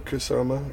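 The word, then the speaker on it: Kusama: Kasama, her last name? – Yes.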